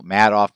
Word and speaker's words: Madoff: You know, Madoff, Madoff, depending on who you want, how you pronounce that, but everybody seems to think Madoff sounds better because he kind of made off with a lot of money.